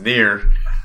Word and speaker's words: Nier: it's pronounced near. That'll be uh, next uh, podcast.